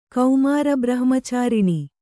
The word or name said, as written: ಕೌಮಾರಬ್ರಹ್ಮಚಾರಿಣಿ